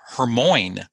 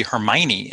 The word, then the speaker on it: Hermione: So I was pronouncing Hermione's name Hermoine, because I didn't know that it was supposed to be Hermione.